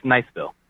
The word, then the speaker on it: Niceville: It's Niceville.